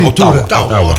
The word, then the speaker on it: Ottawa: Mimmo Idone, ciao da Ottawa si legge così, Ottawa.